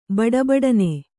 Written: ಬಡಬಡನೆ

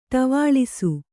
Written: ಟವಾಳಿಸು

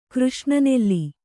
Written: ಕೃಷ್ಣನೆಲ್ಲಿ

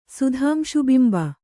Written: ಸುಧಾಂಶುಬಿಂಬ

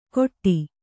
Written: ಖೊಟ್ಟಿ

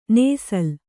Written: ನೇಸಲ್

